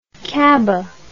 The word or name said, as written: cabas